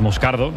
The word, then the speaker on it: Moscardo: Como bien ha pronunciado Unai es Moscardo, es brasileño, sí.